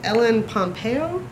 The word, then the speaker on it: Pompeo: But I went with, and I'm going to say her name wrong, Ellen Pompeo. – You said it right.